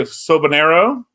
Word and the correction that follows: Soberano: Jr.? – Soberano. – Close. Soberano. Yeah. Sober, like as in sober as in I don't drink.